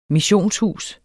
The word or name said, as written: missionshus